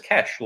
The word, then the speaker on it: cache: So it actually says on the GC page, you pronounce it cash, like money.